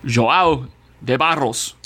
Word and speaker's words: João de Barros: João de Barros. – João. – Okay. I know a João. – You gotta roll your R's though, dog. You gotta be like, João de Barros.